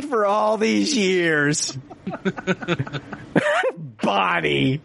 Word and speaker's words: Bonnie: How to pronounce Bonnie? All these years I've been watching One Day at a Time, and I've been saying Boney Franklin all these years.